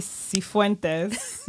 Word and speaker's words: Sifuentes: So in studio with Nikki Leanne and Gabby Cifuentes. Say, yes. Say it right? – No, you are 100%.